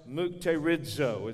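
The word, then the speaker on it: mukterizo (μυκτηρίζω): It is the Greek word which is pronounced mukterizo. It's kind of a weird pronunciation of that.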